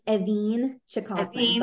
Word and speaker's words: Evîn Cheikosman: Yeah, it's Evîn Cheikosman, but Evîn, okay, with the stress on the second syllable.